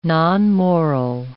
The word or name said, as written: nonmoral